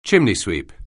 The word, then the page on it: chimneysweep: anglais